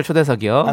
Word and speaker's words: 초대석: 스페셜 소세석이 아니고요. 네, 발음을 좀 정확하게. 예. 네, 스페셜 초대석이요.